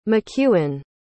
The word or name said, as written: mcewen